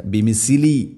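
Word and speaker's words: ബിമിസ്ലി (بمثل): ഫഇൻ ആമനു ബിമിസ്ലി, ഫ എൻ്റെ ഉച്ചാരണം ശ്രദ്ധിക്കുക, ബിമിസ്ലി സീനല്ല ഫ, ബിമിസ്ലി.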